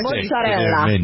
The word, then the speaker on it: mozzarella: Mozzarella. You got to roll your L's.